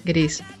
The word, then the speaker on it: Grease: Grace. – Gris. – Salió en la película Grace. – Gris. – Gris. gris. ¿Es gris? – Creo que se pronuncia gris, grasa, vaselina.